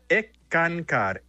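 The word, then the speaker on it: Eckankar: Se pronuncia ECCANCAR, S-K-A-N-K-A-R.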